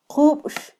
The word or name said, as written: qubx